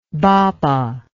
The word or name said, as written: Bhabha